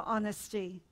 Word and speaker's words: honesty: O is for honesty. I know the H is silent, but O is for honesty.